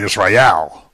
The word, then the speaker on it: Israel: You know, we say Israel, but it's really Israel, because that's the important syllable.